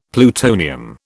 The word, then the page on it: plutonium: British English